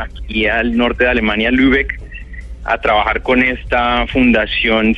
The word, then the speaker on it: Lübeck: Lübeck, muy bien pronunciado, perfecto.